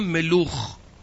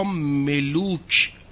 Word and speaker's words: ملوخ: شما در میان بت پرستا یه بوتی میبینید به نام ملوخ یا ملوک، چونها که و خه رو عبری تلفظ میکنن، ملوخ یا ملوک.